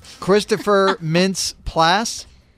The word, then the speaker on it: Christopher Mintz-Plasse: Christopher Mintz-Plasse.